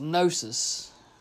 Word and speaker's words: Gnosis: You see the title of this one is what's pronounced Gnosis, which is knowledge in Greek.